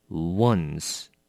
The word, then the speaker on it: once: OK, once 这个字跟我们拼的一还蛮像的，一叫做 one，O-N-E，那么它是 once，那你也要小心它的发音，它不是念成 on，no，它是 once。